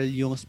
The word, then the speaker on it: Jung: Carl yung pala pronunciation, no? Akala ko Carl Jones.